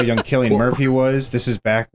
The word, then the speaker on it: Cillian: It's pronounced with a hard C. It's Cillian Murphy. Didn't know that. – Chilean Murphy.